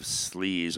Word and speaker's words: Sleze: That way, S-L-E-Z-E, Slez.